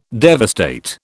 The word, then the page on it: devastate: British English